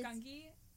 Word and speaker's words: Gungi: Instead, I have to get an entire episode of Gungi, which they didn't even pronounce it right in the show. – Yeah, I don't. They're deciding it's Gungi now. – I hate that. It's Gungi. – It's Gungi. – But it's a hard G.